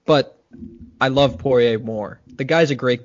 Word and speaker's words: Poirier: Lead into UFC 257, Conor McGregor versus Dustin Poirier? Is that how you pronounce it? – Poirier.